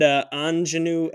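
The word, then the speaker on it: Aunjanue: Kirsten Dunst for Power of the Dog, and Anjanue Ellis for King Richard. – That's actually a really good. You said that pretty well, because I was watching so many YouTube videos.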